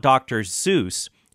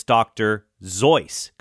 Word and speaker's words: Seuss: It's not Dr. Seuss, it's Dr. Zeus, because it's German. Theodor Geisel. His full name is Theodor Zeus Geisel. It's a German name. It's not Seuss doesn't rhyme with goose. It rhymes with voice.